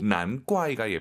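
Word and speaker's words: 难怪: 난怪가 예뻐요. 난怪가 예뻐요. – 다 예뻐요. – 다 예뻐요. 근데 쌍기역으로 난과이 이렇게 발음하는 중국인도 있죠. 근데 우리 왕 선생님은 난과이 기역 느낌으로 발음을 했다는 걸 참고하시면 좋을 것 같습니다.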